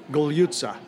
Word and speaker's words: Gugliuzza: Spell your name for us. – My last name. – Spell it. – Gugliuzza. – And pronounce it. – Gugliuzza. – Gugliuzza. Okay, Perfect, Tommy. You said it perfect.